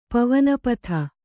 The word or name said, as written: ಪವನಪಥ